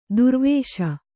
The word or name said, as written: ದುರ್ವೇಶ